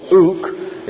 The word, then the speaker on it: ouk: The other one is ouk. It's spelled O-U-K.